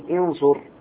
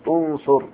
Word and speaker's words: انصر: إذا كانت عين الفعل المضارع مضمومة فإن الهمزة تلفظ مضمومة. انصر، انصر، لا تقول انصر، قل انصر، هكذا بضم الهمزة.